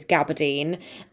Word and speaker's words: gabardine: I have been told since recording this episode that the pronunciation of this fabric is gabardine. So me and Hazel have said it wrong a bunch of times, but, you know, we didn't know.